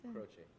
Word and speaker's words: Croce: It's spelled C-R-O-C-E. – Okay. – Croce.